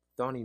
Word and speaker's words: Thandiwe: So, Thandiwe Newton. – Thonny Newton. – Thank you.